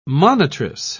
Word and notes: monitress: British English

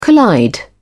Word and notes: collide: British English

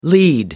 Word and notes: lead off: American English

